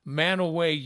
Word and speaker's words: Mantua: And they're not going to know about Manaway, Utah, which is spelled Mantua, or Scipio, Utah, which people say Scipio all the time.